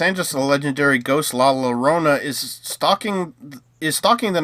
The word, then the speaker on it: Llorona: La Rona, La Llorona, The Curse of La Llorona.